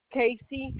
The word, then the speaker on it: Casey: A-N-A-S-T-A-S-S-I-A. – And your last name, please, dear? – Casey, C-A-S-E-Y.